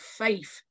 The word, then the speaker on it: Fife: I'm from a place called Fife.